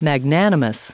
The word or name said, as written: magnanimous